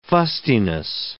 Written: fustiness

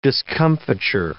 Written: discomfiture

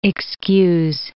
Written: excuse

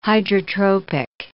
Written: hydrotropic

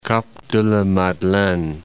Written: Cap-de-la-Madeleine

